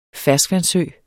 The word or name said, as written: ferskvandssø